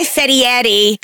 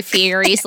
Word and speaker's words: Fieri: Never pronounce Guy Fieri's last name like he's actually Italian. – Fieri. – Oh my God. – That's how you're supposed to pronounce it. – That's not how they pronounce it in Flavortown, okay? – Fieri.